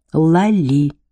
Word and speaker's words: Лали: Отец зовет ее Ума, а мать — Лали, с ударением на последнем слоге. Лали.